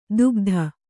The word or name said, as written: ದುಃಖದ